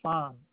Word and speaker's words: Phan: J-O-S-E-P-H. First name. Last name of Fan. P-H-A-N.